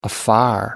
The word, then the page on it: afar: American English